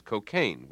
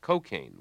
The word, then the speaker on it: cocaine: Even the pronunciation of the word cocaine has changed. Up to a generation ago, the only standard pronunciation was cocaine, with a stress on the second syllable, as in Cole Porter's line, "I get no kick from cocaine." Now it's common to hear newscasters talking about cocaine, with a stress on the first syllable, a street pronunciation that comes from the speech of inner-city blacks.